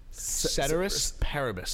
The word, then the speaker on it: ceteris paribus: Say that again. Let me get a pronunciation right. – Ceteris paribus.